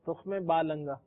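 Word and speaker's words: تخم بالنگا: اصل ہے کیا تلفظ کیا سر؟ – تخ میں با لنگا.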